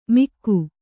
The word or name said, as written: ಮಿಕ್ಕು